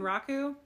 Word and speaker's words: Bunraku: It's before Kabuki, but it spawned Kabuki and something called Bunroku, I think is how you say it, right? Mm-hmm. Bunraku? – Yeah.